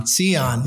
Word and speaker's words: Sehon: I said that right, correct? – Sehon.